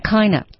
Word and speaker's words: kind of: Of 啊，它的这个地道的发音呢，就是变成 kinda，kind of 变成 kinda。